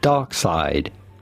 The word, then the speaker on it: Darkseid: It's simply Darkseid. – So it is Darkseid, not Darkseid. – Darkseid. All right. – D-A-R...